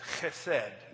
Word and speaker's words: chesed: No, I don't have a cough, but it's chesed. That's how you say it.